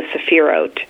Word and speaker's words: Sephirot: which are called the sephirot. – Say that word again. – Sephirot, S E P H I R O T, Sephirot.